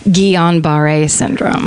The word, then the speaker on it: Guillain-Barré: Oh, here's how you pronounce it. – Guillain-Barré syndrome.